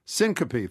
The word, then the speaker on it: syncope: By the way, how do you pronounce, is it syncope? Is that how you say that word? – Syncope, thank you.